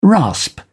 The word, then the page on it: rasp: British English